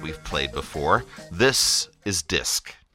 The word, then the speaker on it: Disq: Back on the Athens 441 playlist, that was Disc, spelled with a Q, and their song Daily Routine.